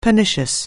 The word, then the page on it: pernicious: anglais